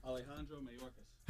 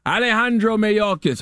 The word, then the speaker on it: Alejandro Mayorkas: Alejandro Mayorkas. – Alejandro Mayorkas.